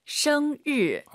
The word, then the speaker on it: 生日: 정말 많은 분들이 生日, 生日, 르. 한글의 르 있잖아요. 그게 들리면 안 되죠. – 没错. – 성 하시고 입 벌린 상태에서 生日. 혀만 천장에 대면서 드럭 生日.